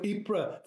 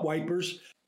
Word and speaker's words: Ypres: You know, Ypres, fine, I got that. It's not Wipers.